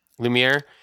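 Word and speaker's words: Lumière: Lumiere.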